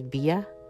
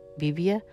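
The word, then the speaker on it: BBIA: First of all, I'm gonna talk about this one called, I think you pronounce this Bia. It's B-B-I-A. Bibia? Maybe Bibia? Bibia Last Lipstick Red Series Velvet Matte in 12 Fantasy.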